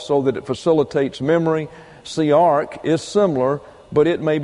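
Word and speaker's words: siach: It's S-I-A-C-H, pronounced siarch, and it means to rehearse or to go over a matter in one's mind.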